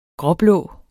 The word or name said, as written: gråblå